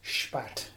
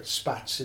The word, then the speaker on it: Szpadt: So it's unique because all my family who are now living in Germany, they spell it S-P-A-D-T. – And it's pronounced Spatt is the proper way. – Okay. – But Spatz is the common pronunciation, as people call me. I've seen it spelled numerous different ways, but the S-Z-P-A-D-T is the main spelling.